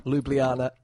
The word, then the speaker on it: Ljubljana: And it was found in, and I can't pronounce it, but Ljubljana? – Ljubljana.